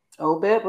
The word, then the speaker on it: Obed: Was it Obed? – Obed was his name.